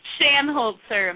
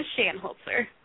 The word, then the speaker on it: Shanholtzer: Shanholtzer, McKenna Shanholtzer.